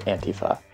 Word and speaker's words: Antifa: So I pronounce it Antifa. – So Antifa. – Yeah, and let's go with Peter's pronunciation, at least for this story, because this is kind of really Peter's story.